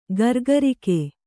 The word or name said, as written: ಗುರಿಗಾರಿಕೆ